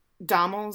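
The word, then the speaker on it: Domhnall: But anyways, this show is done by his brother, and his brother plays the main character, Frank, and then Dommel, is that how you say? I think it's pronounced like Donald, but it's spelled like Dommel.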